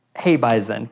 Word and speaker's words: Hebeisen: Aaron, did I pronounce that right? Hyveson? – Hey, Bison. Close enough.